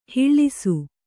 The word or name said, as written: ಹೊಳೆಸು